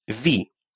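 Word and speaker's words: vid: Ви Обратите внимание на то, что буковка «Д» не читается.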